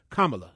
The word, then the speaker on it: Kamala: Did I get her name right? Kamala? – Kamala. – I want to make sure I get it right, before Kamala.